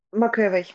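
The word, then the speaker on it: McAvoy: Джеймс Маковой. Макэвой. Угу. Макэвой, правильно произносить его фамилию.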